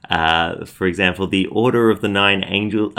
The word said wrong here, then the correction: Angles: Uh, sorry, the Order of the Nine Angles. I always mispronounce it. I want to say angels, but no. Order of the Nine Angles uh,